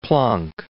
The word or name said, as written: Planck